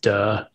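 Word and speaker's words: duh (德): Unfortunately, it's pronounced duh in modern Mandarin, but it's D-E.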